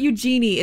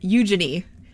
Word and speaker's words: Eugenie: It's not Eugenie, it's Eugenie.